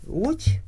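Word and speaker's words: Łódź: Og nu vil jeg ganske kort præsentere bogen De Fattige i Lodz, eller Lodz, som er på 661 sider og oversat til dansk af Niels Lyngse. Den gamle tekstilby Lodz, på polsk udtales det Lodz, blev under den tyske besættelse omdøbt til Litzmannstadt efter den tyske general og nazist Karl Litzmann.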